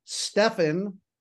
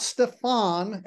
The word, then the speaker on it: Stefan: So the name is Stefan, not Stefan. Everybody thinks the accent should be on the second syllable because the last name, everybody thinks the name should be exotic.